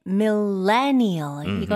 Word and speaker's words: millennial: 네, 밀레니얼. 이거는 강세에 신경 써주세요. 레 이렇게 강하게 해주시면 돼요. 밀레니얼.